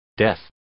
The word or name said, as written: death